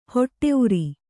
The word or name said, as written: ಹೊಟ್ಟೆಉರಿ